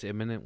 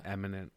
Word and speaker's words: imminent: That's imminent with an I, not eminent with an E.